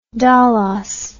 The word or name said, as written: dolos